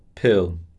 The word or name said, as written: pill